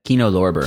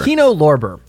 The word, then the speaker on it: Kino Lorber: Kino Lorben? How do you say it? – Kino Lorber. – Kino Lorber. I'll learn how to say it one of these days.